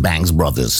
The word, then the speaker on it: Banks Brothers: The Bankies Brothers, The Banks Brothers, so spricht man es eigentlich richtig aus.